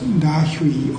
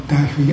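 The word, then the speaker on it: Dahui: But Dai E, let me use Japanese pronunciation, because I really cannot pronounce Chinese correctly. So let me call Da Hui as Dai E.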